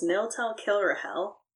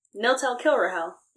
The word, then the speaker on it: Niltel Kilrahel: So my character's name is Niltel Kilrahel. – And what time? – Niltel Kilrahel. It rhymes.